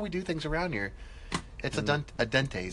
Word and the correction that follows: al dente: sir. Is that how you say it? – A dente? Al dente, sir.